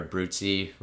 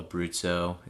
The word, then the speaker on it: Abruzzo: Uh, but I, I learned a little bit of, about the Abruzzo or Abruzzi region, that it's all Abruzzo, it's spelled Abruzzo.